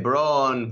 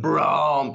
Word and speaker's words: Braun: Esto de Braun, Braun, perdón, que no lo he dicho bien, y Kane,